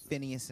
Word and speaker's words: Phineas: So when so what happened is that basically the sons of Eli, and I can't pronounce it's Phineas and Hophni. – Yeah, Phineas and Hophni.